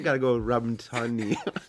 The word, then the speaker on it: Tunney: Well, I want to say because I just started the fan club, Robin Tooney. – I've been Tunney, by the way. – Tunney. – You know how to pronounce the name right.